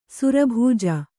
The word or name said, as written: ಸುರಭೂಜ